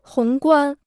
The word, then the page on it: 宏观: hóng guān